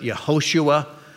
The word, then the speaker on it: Yehoshua: In the Hebrew, you can pronounce it Yehoshua.